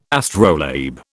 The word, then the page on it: astrolabe: British English